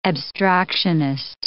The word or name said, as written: abstractionist